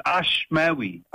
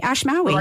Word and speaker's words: Ashmawy: It's, it's, it's, it's, it's actually very phonetic. It's Ashmawy. Okay, Ashmawy.